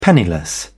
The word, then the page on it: penniless: British English